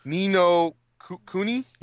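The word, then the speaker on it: Ni no Kuni: Nino Kuni? – Yep, and I already have it reserved.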